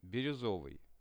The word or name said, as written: biruzovyi